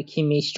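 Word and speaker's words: Kimmich: شما آلمانی بلد نیستی سال نظر نکن اولا که یه مصاحبه داره کیمیش چون که اسم کوچیکش یوسوا تلفظش توی آلمانی هم عجیب غریبه یعنی اسمی که شنیده نشده ازش پرسیدن اسمش تلفظ کنه اون مصاحبه موجوده تلفظ اسمش رو از زبون خودش میتونی بشنوی یوسوا کیمیش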